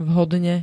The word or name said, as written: vhodne